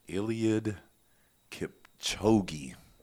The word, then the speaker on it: Eliud Kipchoge: Eliud Kipchoge.